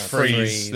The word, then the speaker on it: Fries: But Fries is pronounced Freeze.